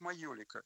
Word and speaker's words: майолика: И в итальянской транскрипции это звучит как майолика.